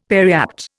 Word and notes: periapt: Indian

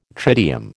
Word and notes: tritium: American English

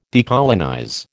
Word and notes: decolonize: American English

